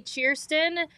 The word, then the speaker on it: Chierstin: Well, explain to people how you like break down your name when they like ask how to pronounce it. Because you got a really good like phonetic way of breaking it down. – Yeah, because even when I say Kirsten, people just automatically just don't think that's a name.